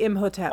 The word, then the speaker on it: Imhotep: Is it Imhotep or is it Imhotep? – It would be Imhotep.